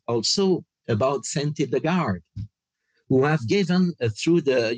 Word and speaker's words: Hildegard: Hildegard, yeah.